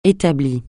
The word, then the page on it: établi: français